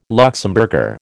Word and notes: Luxemburger: American English

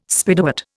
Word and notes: Spiderwort: Indian

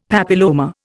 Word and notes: papilloma: Indian

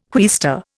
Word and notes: quaestor: Indian

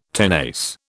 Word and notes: tenace: British English

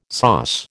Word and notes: SOS: American English

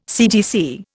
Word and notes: CTC: Indian

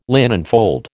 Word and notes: linenfold: American English

